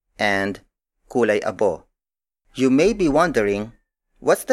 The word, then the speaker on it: kulay abo: Another variation is kulay abo.